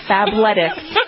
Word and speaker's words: Fabletics: Who the hell would name a fashion line Fable ticks? – It's like lettuce, like athletics, and it's fab. Fabletics.